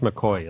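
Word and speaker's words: McCaughey: So it actually checked on this, and I'm convinced they were right, that this Betsy, I can't remember, McCoughlin, was that? – It's pronounced McCoy. It looks like McCahey.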